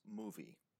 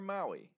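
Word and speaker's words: Mowi: They're going to be known as Movi, or Maui, if you read it in English.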